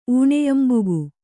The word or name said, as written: ಊಣಯಂಬುಗು